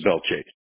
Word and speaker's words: Belle Chasse: Now, I see you live in the city of Belle Chasse, I believe is how you say it? Chasse? – Belle Chasse, yes.